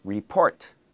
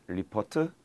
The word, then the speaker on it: report: The second one is report, report. Again, notice the end is just one syllable, not ripotu, report, port.